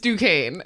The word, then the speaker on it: Duquesne: We were like, oh, I hope Duquesne wins. – Okay, now I already forgot the correct way. I believe it's Duquesne. – It's Duquesne. – Okay, a few things here. First of all, Duquesne.